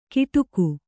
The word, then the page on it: ಗಿಟುಕು: giṭuku